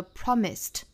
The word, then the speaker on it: promised: 我们看到这两个单词 look 和 promise 尾部都是清辅音，分别是 k 和 s，所以它们要变成过去式的话呢，应该读成 looked 和 promised，这就是清清浊浊了。